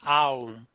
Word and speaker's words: Om: Om is not one vowel, it's several vowels. It's supposed to be pronounced Aum.